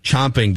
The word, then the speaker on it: champing: It's it's pronounced chomping, but it's spelled champing.